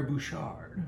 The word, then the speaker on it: Bouchard: By French Captain Pierre Bouchard. – Bouchard. Nice job with the French there.